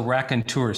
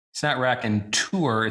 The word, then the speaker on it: raconteur: R-A-C-O-N-T-E-U-R. So raconteur. So it's not raconteur, it's raconteur.